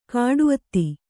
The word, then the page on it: ಕಾಡುಅತ್ತಿ: Kannada